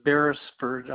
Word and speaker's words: Beresford: Keith Beresford.